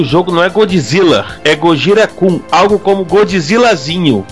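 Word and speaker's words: Gojira (ゴジラ): E como a gente comentou, em japonês é Gojira.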